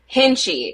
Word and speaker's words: Hintzsche: Hintzsche. It's way Hintzsche.